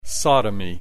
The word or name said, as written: sodomy